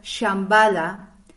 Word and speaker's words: Shambhala: cuando yo oigo la palabra Shambhala, yo creo, y corregirme si estoy equivocada, por favor, de que la SH es sh, no como una che.